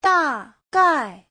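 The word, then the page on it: dagai: da4gai4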